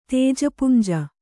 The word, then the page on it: ತೇಜಪುಂಜ: tēja punja